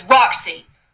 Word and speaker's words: Rock-C: My name is Roxy. R-O-C-K hyphen C.